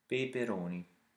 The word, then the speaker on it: peperoni: Peperoni.